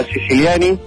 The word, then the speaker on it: Siciliani: Mi apellido se pronuncia Siciliani, igual que el de Griselda, pero no se escribe igual.